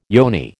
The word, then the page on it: yoni: American English